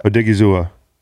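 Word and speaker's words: Odighizuwa: And so you could see him unlocking the other rookie they drafted, Osa Odiguza. I think I got to learn his name. – We're really good at pronouncing names on this podcast. – Odiguzua. – Damn, you nailed it.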